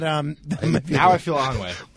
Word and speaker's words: ennui: Onway. How do you spell Onway? – E-N-N-U-I. – E-N-N-U-I? – Yes.